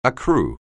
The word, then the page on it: accrue: anglais